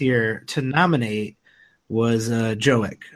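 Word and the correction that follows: Jokić: or uh, how how do you say Jokic. Jokic.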